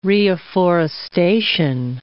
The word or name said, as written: reafforestation